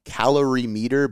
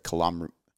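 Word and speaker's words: calorimeter: calom- calorie meter, but I think it's not pronounced like kilometer. But it's not kilometer, like a distance.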